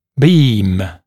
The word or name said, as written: beam